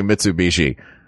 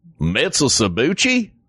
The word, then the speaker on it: Mitsubishi: We had a commercial on TV for years that was just people trying to Knoxvillians trying to say Mitsubishi, which made me laugh. – What was the worst mispronunciation? – Mitsusabuchi was my favorite.